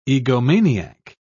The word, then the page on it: egomaniac: British English